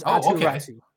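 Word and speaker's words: Aatu Räty: It is Finnish pronunciation, and it is Atu Ratu.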